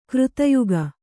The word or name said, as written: ಕೃತಯುಗ